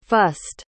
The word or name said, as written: fust